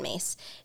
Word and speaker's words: Myss: Her name is actually spelled Caroline, but she goes by she pronounces it Carolyn, and Mace is actually M-Y-S-S, in case anyone wants to look her up.